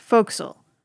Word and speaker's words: forecastle: It looks like the word forecastle, but it's pronounced forecastle. Or if you're from some parts of New England, like my former advisor, forecastle.